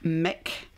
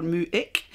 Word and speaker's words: Muick: So, pronounced Loch Mick at times, or Muick, depends whether you're talking with somebody that speaks Gaelic, I guess, these days, right.